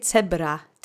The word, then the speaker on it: cebra: Okay, let's say cebra together three times. Accent is on the first syllable.